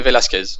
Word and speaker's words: Velasquez: Je ne sais pas comment ça se prononce. Velasquez? – C'est ça, Cain Velasquez.